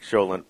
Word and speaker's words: cholent: Cholent? – Cholent, yeah.